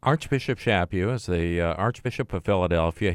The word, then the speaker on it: Chaput: In an article by CNA, EWTN News, talking about Archbishop Chaput. Am I saying that right? Chaput. – Mm-hmm.